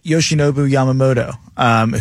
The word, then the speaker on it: Yoshinobu Yamamoto: But let's go and look at Yoshinobu Yamamoto, who is kind of the biggest name left, probably.